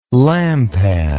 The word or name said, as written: lampad